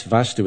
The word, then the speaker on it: vastu: That's vastu with a long A.